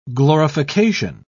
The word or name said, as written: glorification